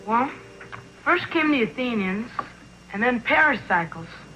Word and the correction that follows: Pericles: Well, I think that's pronounced Pericles.